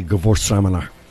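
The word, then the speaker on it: Gewürztraminer: How do we say it? What is the correct pronunciation? – I would say Gewurztraminer.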